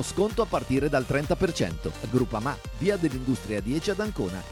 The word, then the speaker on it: Groupama: Groupama, ah no, si pronuncia Groupama, di Paolo Felicetti.